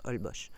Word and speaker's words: Holbox: qui s'écrit Holbox avec un X à la fin, mais ça se prononce Holbox.